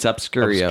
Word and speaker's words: Obscurio: How would you say it in American? Obscurio. – Yeah, it's Obscurio.